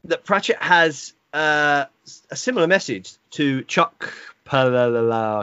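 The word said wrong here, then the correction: Palahniuk: I can't pronounce his surname. Polonick, that's it.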